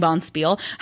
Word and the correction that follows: bonspiel: However, I Googled it because, of course, and a bond spiel is quite simply just a curling tournament. – Okay. – And it is pronounced Bonspiel.